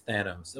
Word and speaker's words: Thanos: Someone's going to be like, it's Thanos.